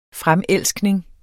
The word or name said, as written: fremelskning